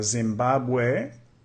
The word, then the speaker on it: Zimbabwe: Zimbabwe. Casi lo decimos igual en inglés. Zimbabwe. Zimbabwe. Con énfasis ahí en la segunda sílaba.